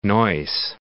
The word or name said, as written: Neuss